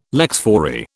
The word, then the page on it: lex fori: British English